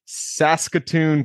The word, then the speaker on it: Saskatoon: Today we have Chris Molinar with us from north of North Dakota, per Chris's words, up there in Saskatoon, Canada. Did I say that right, Chris? – That's it. You bet.